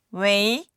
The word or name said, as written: wei2